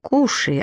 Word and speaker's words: Куши: В отличие от черных собак, куши, от английского кусы, произносится не по правилам, в легендах зеленого цвета.